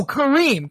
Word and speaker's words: Kareem: Oh, Kareem. Coffee and Kareem. – Kareem. Okay. I was going to say, I might have said it wrong because it's Kareem. Because the pun, it's like coffee and Kareem.